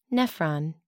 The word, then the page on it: nephron: American English